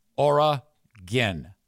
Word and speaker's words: Oregon: Oregon. That's how you say it. Oregon. You can even get away with Oregon. No, you can't. Oregon. Three syllables, ends with gin. Not gone. Oregon.